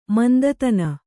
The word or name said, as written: ಮಂದತನ